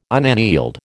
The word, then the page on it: unaneled: American English